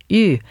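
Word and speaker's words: Hüe: We'll start off this hour with a fantasy by French composer Georges U. Sounds like a weird name, but it's spelled H-U-E.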